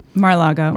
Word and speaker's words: Mar-a-Lago: Mar-a-Lago.